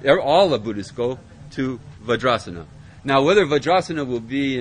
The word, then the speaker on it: Vajrasana: Vajrasana. Very good. See? You're learning. So that would become a long A. That would become a long A there. Vajrasana.